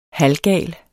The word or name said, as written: HALVGAL